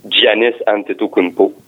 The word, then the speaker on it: Giannis Antetokounmpo: Euh, écoutez, puis d'autres équipes comme les Bucks avec euh, Giannis Antetokounmpo.